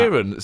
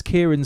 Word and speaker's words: Kiran: It's Kieran. So it's Kieran, but it's a woman. – It's spelt K-I-R-A-N. It's Kieran Singh.